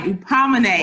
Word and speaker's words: Hupomoné: Hupomoné.